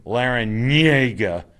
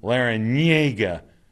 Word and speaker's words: Larrañaga: And Jim Larrañaga, that's how you say his name, Larrañaga.